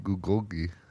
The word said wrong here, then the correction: bulgogi: It's bulgogi. – It's bulgogi with a B.